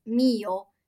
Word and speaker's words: Mio: And I kept seeing, in my head, it was Mayo. I kept saying Mayo. And then someone corrected me, like, no, it's Mio.